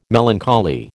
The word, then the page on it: melancholy: American English